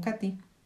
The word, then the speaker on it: Kate: como la que me contó mi tía Kate, pronunciado como Katy, que vivía en Sibrak, cerca de, de en Hungría.